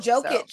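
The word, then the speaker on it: Jokić: Before we get into your Lakers, I did want to talk a little bit about the Josevic. I can't even say his name. – Oh, Jokic. So.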